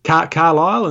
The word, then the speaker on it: Carlisle: And he lives in, how do you say that, Castle? – Carlisle in Northern England.